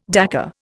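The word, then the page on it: décor: Indian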